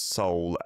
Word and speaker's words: Seoul: Miss Andriana is saying, "In the Indonesian sphere, we pronounce Seoul as S-O-U-L. Well, that'll be how it is pronounced, like the same as soul in English to mean, you know, what's inside of you that might live on after you die.